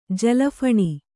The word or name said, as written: ಜಲಫಣಿ